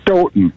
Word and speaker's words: Stoughton: You actually, the second time you said it right, Stoughton. They pronounce it Stoughton. – Like Houghton, Michigan.